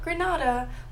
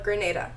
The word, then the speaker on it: Grenada: Not Grenada, but Grenada.